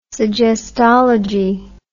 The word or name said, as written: suggestology